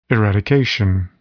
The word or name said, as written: eradication